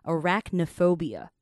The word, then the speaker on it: arachnophobia: 叫做 arachnophobia Arachnophobia.